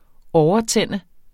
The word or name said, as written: overtænde